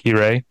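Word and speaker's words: Monsieur Hire: Spelled like Hira, but like Hirei.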